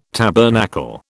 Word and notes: tabernacle: British English

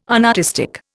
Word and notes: unartistic: Indian

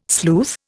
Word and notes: sleuth: Indian

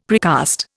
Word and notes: precast: Indian

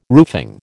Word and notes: roofing: American English

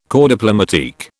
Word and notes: corps diplomatique: British English

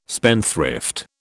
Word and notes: spendthrift: British English

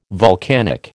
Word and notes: volcanic: American English

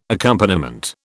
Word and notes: accompaniment: British English

